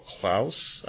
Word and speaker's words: Klaus: They have an animated show coming out called Klaus, I think it's called. – Like Claus, Santa Claus? – Yeah, that's probably how it's pronounced.